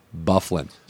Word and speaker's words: Byfuglien: I can't spell that guy's name because it's B-E-A-U-G-H-L-I-Q-U-E-A, whatever. – Yeah. – But his name is pronounced Byfuglien.